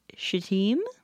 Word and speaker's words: Shittim: They're staying in a place that is probably pronounced Shittim, but looks like Shittim.